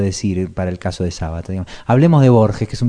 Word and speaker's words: Sabato: Ernesto Sábato que se escribe sin acento descubrí el otro día horrorizado me sigue pareciendo genial es una gran parte del libro que demuestra que, bueno, que Sabato podía escribir muy bien.